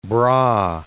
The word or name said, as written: Brahe